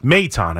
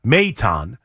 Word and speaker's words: Maton: considering last year they did trade for Phil Maton at, am I saying that right? Why did that come out wrong? – Maton. – I knew as soon as I said it, that didn't sound right. Maton last year on July 10th.